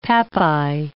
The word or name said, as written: pappi